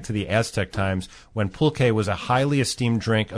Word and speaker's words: pulque: Pronounce it one more time for me, Jeff. P-U-L-Q-U-E. – Pulque.